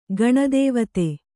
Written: ಗಣದೇವತೆ

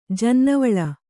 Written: ಜನ್ನವಳ

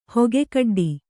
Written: ಹೊಗೆಕಡ್ಡಿ

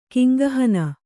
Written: ಕಿಂಗಹನ

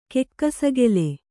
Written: ಕೆಕ್ಕಸಗೆಲೆ

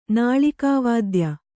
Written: ನಾಳಿಕಾವಾದ್ಯ

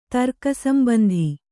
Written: ತರ್ಕಸಂಬಂಧಿ

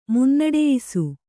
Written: ಮುನ್ನಡೆಯಿಸು

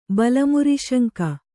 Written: ಬಲಮುರಿಸಂಕು

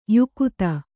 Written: ಯುಕುತಿ

